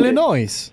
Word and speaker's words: Illinois: Illinois. – What was that? – Illinois. There's an S at the end. – You knew where you were going. – Yes, and it's a silent S.